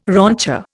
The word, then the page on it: rancher: Indian